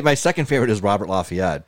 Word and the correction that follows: La Follette: Yeah. They usually pronounce it La Follette over there. I don't know if it's like a weird Buffalo pronunciation.